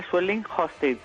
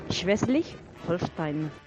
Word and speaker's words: Schleswig-Holstein: Ah, lo hago yo. Para que tú lo digas bien, Schleswig-Holstein.